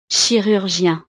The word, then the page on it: chirurgien: frances